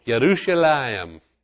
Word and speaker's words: Yerushalayim: Finally, we get to Jerusalem. Yerushalayim. That's how you pronounce it.